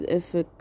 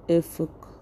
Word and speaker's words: Efik: Um, Closely related is Efik. So people call it Efik, but it's actually pronounced Efuk.